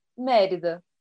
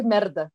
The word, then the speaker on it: Mérida: É melhor do que merda, né? Que fica parecendo merda. – Despeito, eu falei assim, eu tô pronunciando correto, porque em inglês funciona, agora em português funciona. – Não, é porque em inglês eles falam com sotaque escocês. Aí sai merda. Aí, tipo, parece merda.